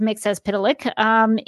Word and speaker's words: Mxyzptlk: On the CW Supergirl, they pronounce it as Mxyzptlk. Two actors have played Mxyzptlk.